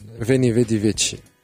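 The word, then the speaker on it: Veni, vidi, vici: لحظة صلحوا لي اللي باش تعرفوها فيني فيدي فينشي فيني فيدي فيتشي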